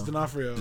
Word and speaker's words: D'Onofrio: I don't know how to say his last name. – D'Onofrio. – It's D'Onofrio.